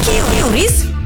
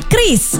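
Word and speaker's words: QRIS: Ada yang menyebut Kiris, Kiris, atau Oris. Kepala Grup Departemen Kebijakan Sistem Pembayaran DKSPBI, Fitria Irmi Triswati mengungkapkan, pengucapan yang benar adalah Kris. Jadi, jangan salah ucap lagi ya.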